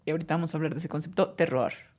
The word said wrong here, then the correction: terroir: El terroir. Que se, que se escribe terror.